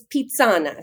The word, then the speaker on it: Pizzana: Is it pronounced Pizzana? – You're very close. It's Pizzana. – Pizzana. – So just, you know, add your inner Italian and you're all set.